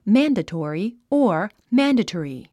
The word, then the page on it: mandatory: mǽndətɔ̀ːri; マンデトリィ